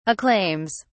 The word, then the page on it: acclaims: British English